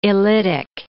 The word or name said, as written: illitic